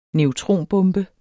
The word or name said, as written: neutronbombe